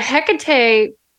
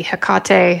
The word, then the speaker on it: Hecate: I work with both of them and they're similar because they're both dark goddesses, but Hecate really, and it gets pronounced in a lot of different ways. So there's no one, it could be Hecate, Hecate. There's room there for interpretation.